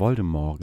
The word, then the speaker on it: Voldemort: Aber der das ist immer der, Voldemort gesagt. Ja, aber das, das ist richtig. Die richtige Aussprache.